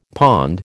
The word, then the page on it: pond: American English